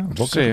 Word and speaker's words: Böker: No, una empresa familiar alemana se llama Böker, ¿se pronuncia? Böker, sí. Con el lado con dos puntitos, es Böker.